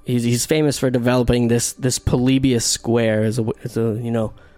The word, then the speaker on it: Polybius: Polybius.